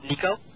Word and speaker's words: Niko: Nico. – Nico. – N-I-K-O. – Okay. – Last of a Kai, A-K-A-I.